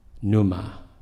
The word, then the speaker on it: pneuma: Interesting that the Greek word for breath and the word for wind and the word for spirit are all the same word, pneuma, with a silent P.